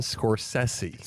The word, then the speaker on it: Scorsese: I want to say this the right way because I always mispronounce his name. Martin Scorsese. – Scorsese. Yes. Get it right.